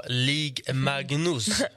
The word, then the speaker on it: Ligue Magnus: Var du och kikade någonting på Ligue Magnus? – Bra uttalat.